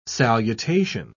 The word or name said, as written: salutation